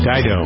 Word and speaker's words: Dido: Dido.